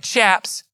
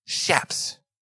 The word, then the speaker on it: chaps: Do you know that's how you pronounce it? It ain't chaps. It's chaps. Now, don't look at me like that. I don't make the rules. Shaps.